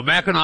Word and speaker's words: Mackinac: When he goes to Mackinac Island? – Oh, no, no, Mackinac Island. – Mackinac Island. – First of all, yeah. Yeah. – It's spelled, why do they spell it Mackinac when it's Mackinac?